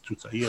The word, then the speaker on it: Tutaia: Tutaia she got, but it's Tutaia is the pronunciation.